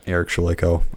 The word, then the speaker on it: Eric Shalayko: And yeah, same for Twitter, Facebook is just at Eric Shalayko on pretty much every platform.